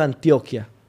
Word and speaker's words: Antioquia: Y aparecí en un barrio que se llama el Barrio Antioquia, que es el barrio que yo digo que yo represento, aunque no nací, no me... – Antioquia. Barrio Antioquia.